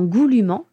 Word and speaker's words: goulûment: Et qu'en revanche, dans goulûment, qui est un adverbe qui nous dit comment, qui vient modifier le verbe comment est-ce que le chat mange, eh bien là on sait qu'on va prononcer le ENT.